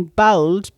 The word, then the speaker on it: Bould: I should explain bowled, B O U L D, is how we pronounce bold here in Cork.